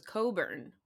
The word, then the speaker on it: Cockburn: Um, I, I, I knew a guy whose last name was Coburn, but it's spelled Cockburn. And my mom couldn't get over it.